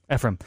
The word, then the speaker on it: Ephrem: Saint Ephrem? – Ephrem.